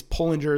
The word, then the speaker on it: Pullinger: In fact, an interesting story on the side, I once read an interview of Jackie Pullinger, I think it's Pullinger is the way to pronounce her name, who has served as a missionary in the darkest parts of Hong Kong since the mid-60s.